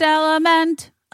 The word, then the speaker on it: LMNT: LMNT, but it's pronounced element.